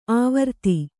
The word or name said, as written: ಆವೃತಿ